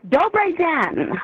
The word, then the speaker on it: Dobrý den: Yes, well, first of all, Dobrý den, which is good morning in Czech.